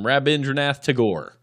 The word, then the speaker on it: Rabindranath Tagore: But hey, we're going to leave you guys with these words of wisdom from Rabindranath Tagore.